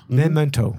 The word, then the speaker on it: Memento: Memento. – Guy Pearce? – It goes, rhymes with pimento.